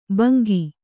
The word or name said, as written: ಭಂಗಿ